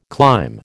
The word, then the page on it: climb: American English